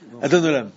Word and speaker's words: Adon Olam: Adon Olam.